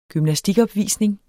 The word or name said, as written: gymnastikopvisning